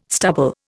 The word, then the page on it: stubble: Indian